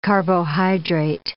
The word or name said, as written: carbohydrate